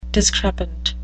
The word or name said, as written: discrepant